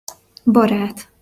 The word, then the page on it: barat: Hungarian